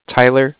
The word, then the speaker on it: Tyler: Again, my name is Tyler. It's T-Y-L-E-R. And I'm calling with Cardiff. It's spelled C-A-R-D-I-F-F, as in Frank.